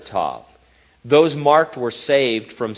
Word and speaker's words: Tav: Well, scholars say that the word, translated mark, is just the last letter of the Hebrew alphabet, pronounced Tav or Ta.